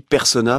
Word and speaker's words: Personnaz: Et alors, normalement, on dit persona parce que c'est un nom à consonance savoyarde. – Ouais. – Et donc, comme Avoria ou Laclusa, on prononce pas le Z.